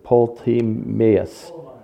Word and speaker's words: Ptolemais: It's Ptolemais.